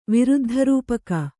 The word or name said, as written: ವಿರುದ್ಧರೂಪಕ